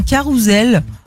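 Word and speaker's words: carrousel: Et puis on ne prononce pas le T à adéquate ou encore carousel qui se dit avec le son Z. Normalement, on dit un carousel.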